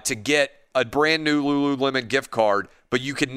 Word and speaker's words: Lululemon: Like, my wife lost her Lululemon, however you pronounce it, Lululemon, whatever, gift card one time. – Yeah, Lululemon.